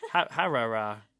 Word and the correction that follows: Harare: So It's H-A-R-A-R-E. Harare.